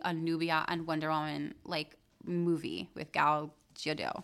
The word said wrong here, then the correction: Gadot: I think that would be, Gadot. – Did I say it wrong? – Mm-hmm. – Okay, thank you for correcting me.